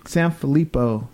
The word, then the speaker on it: Sanfilippo: Carter is a 6-year-old boy who suffers from Sanfilippo syndrome.